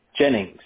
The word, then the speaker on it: Jennings: Jennings. – Could you spell that? – J-E-N-N-I-N-G-S.